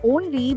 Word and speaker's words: Ownly: Rapido's food delivery platform Only, which is spelled O-W-N-L-Y, has captured around 7% of Bengaluru's food delivery market in just 4 to 5 months of its citywide rollout.